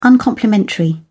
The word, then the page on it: uncomplimentary: British English